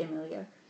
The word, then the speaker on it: Emilia: Amelia Clark. Amelia Clark. No, Amelia Clark. Um, oh. it's, ah. e. Is it E? It's was it's spelled with an e. – Okay, it's probably Emilia.